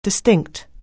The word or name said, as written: distinct